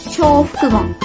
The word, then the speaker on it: 招福門: え、招くに幸福の門と書いて、小福門です。